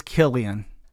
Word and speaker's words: Cillian: It is Cillian. – With a K. – With a K. – Okay. Even though it's spelled with a C.